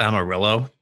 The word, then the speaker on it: Amarillo: Matthew, as the resident Hispanic, it's pronounced Amarillo.